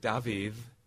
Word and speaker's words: Dafydd: So the name David, spelled D-A-F-Y-D-D, has a the sound. Or the actor Ewan Griffith, his last name is spelled G-R-U-F-F-U-D-D, Griffith.